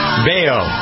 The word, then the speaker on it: Baio: Theme from Happy Days, because it's Scott Baio. I never pronounced it. Baio. – Baio. – But it's spelled bio. – Well, right? – Not really. – B-A-I-O.